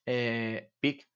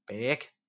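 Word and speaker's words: PEEK: Estos PEC, que se escriben, o PIC.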